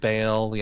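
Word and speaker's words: Baal: Yeah, the pet is called Ball, B-A-A-L, B-A-A, apostrophe L. Yeah, Alex would kill us if we don't point out Ball in Diablo is actually pronounced Bale, yada yada yada. This is Ball.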